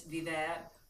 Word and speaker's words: vivere: because it's Italian for to live, but it's vivere, so it's V-I-V-E-R-E.